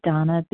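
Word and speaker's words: Dana: This is Donna B.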